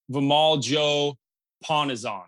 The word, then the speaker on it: Vimal Joe Ponnezhan: So, welcome, my friend, Dr. Vimal Joe Ponnezhan.